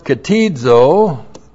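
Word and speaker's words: ktizo: K-T-I-S-I-S. Now, katesis is the noun that comes from the verb katizo. Looks like that. K-T-I-Z, long O.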